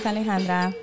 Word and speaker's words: Alejandra: I was telling someone the other day, I'm like, oh, yeah, my name is Alejandra. Now you have a reason to roll your R's.